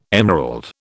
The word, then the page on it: emerald: British English